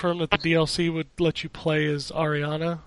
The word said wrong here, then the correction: Aranea: Aranea, I think. – Aranea, yeah.